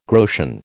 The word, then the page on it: groschen: American English